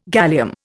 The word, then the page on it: gallium: Indian